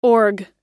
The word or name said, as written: org